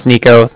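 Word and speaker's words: Niko: All right, yeah, like I said, my name's Nico. That's N-I-K-O. And then the Bank of Cardiff is spelled C-A-R-D-I-F-F, as in Frank, Frank.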